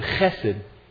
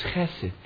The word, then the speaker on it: chesed: Now this word kindness in the Old Testament, let me give you a Hebrew word, chesed. Chesed, H-E-S-E-D is the way you'd spell it in English, chesed.